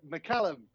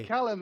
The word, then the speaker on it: McCallum: Did I say your name right? – Whoop, whoop. No, McCallum. – McCallum. Okay. – McCallum, like a fine Scotch whiskey.